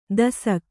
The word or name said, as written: ದೇಸಾಕೆ